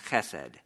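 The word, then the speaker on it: chesed (חסד): If you were to spell it, it'd be H-E-S-E-D. And the H at the beginning is a guttural, which most people think in Hebrew is something you really spit out all over the place. But it really isn't, so I'll try to pronounce it a few times. Chesed, chesed. There's just a ha, ha, ha, just a very light constriction of the throat there.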